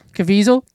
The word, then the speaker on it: Caviezel: Caviezel.